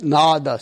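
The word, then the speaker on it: nāda: N-A-D-A. – Nada. – Okay. – And if you want to make that sound longer, nada. – Okay. – So you either put a bar on top of the first A, or it would make double A, N-A-A-D-A. – Yeah, it's spelled two or three different ways. – No, the sound is nada, so you can spell it with an A on top, bar on top, or two A's.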